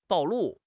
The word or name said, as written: 暴露